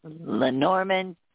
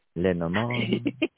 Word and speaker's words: Lenormand: Lenormand. – Lenormand. Enchanté. Yeah, we haven't talked about that in a long time, you know, and we've got the fabulous Brenda Elizabeth. She created the Mysteries Halloween Lenormand.